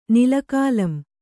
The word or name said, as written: ನಿಲಕಾಲಂ